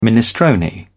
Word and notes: minestrone: British English

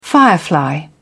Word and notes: firefly: British English

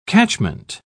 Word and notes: catchment: British English

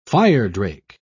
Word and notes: firedrake: British English

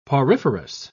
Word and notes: poriferous: British English

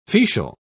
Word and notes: fetial: British English

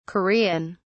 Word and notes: korean: British English

